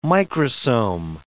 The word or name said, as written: microsome